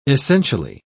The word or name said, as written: essentially